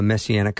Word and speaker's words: Messianic: Because you pastor a Messianic congregation.